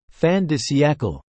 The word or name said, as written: fin de siecle